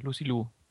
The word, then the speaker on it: Lucy Liu: Wird die so ausgesprochen? – Ja, Lucy Liu.